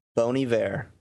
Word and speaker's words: Bon Iver: Bon Iver. – Yes. – But because he's French. – You don't call it Bon Iver.